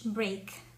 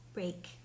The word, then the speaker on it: Braque: Este movimiento fue creado principalmente, o sea, el título de los creadores son número uno, Pablo Picasso, y número dos, George Brake. Se escribe Braque, Brake.